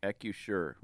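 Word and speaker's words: Acrisure: at Ribfest at, how do you say the name of the stadium? – Accusure.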